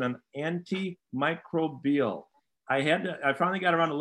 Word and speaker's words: antimicrobial: I was pronouncing it wrong all these weeks that Iron Jock has been our great sponsor. But it's antimicrobial is the pronunciation